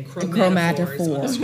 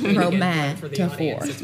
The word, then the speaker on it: chromatophores: Chromatophores. – Chromatophores. – Yes. – Hopefully I'm saying it right, but that's. That's how I've always said it. – That's how you say it.